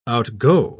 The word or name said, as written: outgo